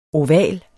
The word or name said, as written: oval